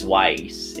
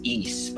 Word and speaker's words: Ys: Oh, yeah, I'll admit, and when I first saw YS, I always pronounced it as Wise. You know, I didn't know how to pronounce it way back when. Wise. Turns out it's pronounced as East, pala.